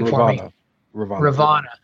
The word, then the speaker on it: Ravonna: Ravonna,